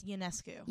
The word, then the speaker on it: Ionescu: Sabrina I think it's is it INESCU? – It's, no, it's Ionescu.